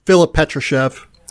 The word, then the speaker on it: Filip Petrushev: And you have a big in Filip Petrushev. Did I say that right? – Filip Petrushev. – He played on our circuit.